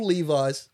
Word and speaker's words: Levis: Third string rookie quarterback Will Levis. – Yeah, I think they were pronouncing it Levis. I always just read it and go Levis.